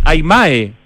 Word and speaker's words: Aymae: Quien lidera un emprendimiento de diseño y de sustentabilidad que se llama Aymar, A-Y-M, perdón, Aymae, ¿no es cierto? – Aymae. – Otra vez dije Aymar, lo tengo en la eso que ni siquiera me gusta el fútbol, pero bueno, Aymae, sí.